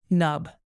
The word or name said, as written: nub